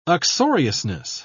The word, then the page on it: uxoriousness: British English